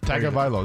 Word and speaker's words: Tagovailoa: Tagovailoa.